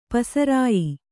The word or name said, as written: ಪಸರಾಯಿ